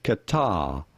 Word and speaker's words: Qatar: Qatar. Qatar.